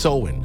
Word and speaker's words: Samhain: It's spelled S-A-M-H-A-I-N, but it's pronounced Samhain, along those lines, Samhain, Samhain.